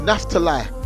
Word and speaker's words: Naphtali: Now I want to let someone know that the word Naphtali, or Naphtali, sorry, I keep saying it wrong. The word Naphtali, if you look at the Hebrew word for Naphtali means struggle.